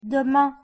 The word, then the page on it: demain: French